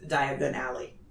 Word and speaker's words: Diagon Alley: And sure enough, it's Diagon Alley. I say it like that because that's how Harry said it. – Mm-hmm. – When they were like, what did he say? He said diagonally.